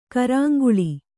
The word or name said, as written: ಕರಾಂಗುಳಿ